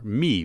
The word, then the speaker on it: My: Bubba is from Iceland, and My, or Me, really, is her name. It's spelled M-Y, but the group is My Bubba.